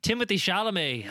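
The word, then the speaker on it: Timothée Chalamet: Timothy Chalamet hosted SNL the other day, Liam.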